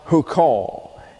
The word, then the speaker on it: huqqa: and that is spelled H-U-Q-Q-A, pronounced hukal. And it comes from a root word, hakak, that's H-A-Q-A-Q, hakak, and that means to engrave.